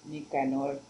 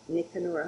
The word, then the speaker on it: Nicanor: Okay, so you said Nicor? – Nicanor. – Nicanor. How do you spell that? – N-I-C-A-N-O-R.